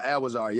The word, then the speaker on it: Adwazar: Sorry, how do you pronounce it? – You said you like one of the only people say it right, but yeah, Adwazar. Yep.